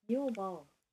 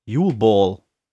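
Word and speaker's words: Yule Ball: Tatsächlich, heißt, gesprochen wird's. – Jule Ball. – Jule Ball.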